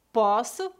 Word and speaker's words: posso: Poço com O fechado. Posso, do verbo poder, com O aberto.